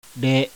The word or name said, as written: RE